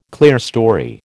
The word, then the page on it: clerestory: American English